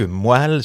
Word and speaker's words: moelle: Il nous dit aussi que moelle se prononce avec le son d'étoile.